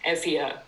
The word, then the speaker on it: Euboea: It's a painted terracotta centaur from the really important early Greek site of Lefkandi on the island of Euboea, or as, to give it its modern pronunciation, Euboea.